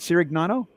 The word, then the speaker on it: Cirignano: So as we continue our discussion here with Doug, and did I say it right, Cirignano? – Yeah, you got it pretty good.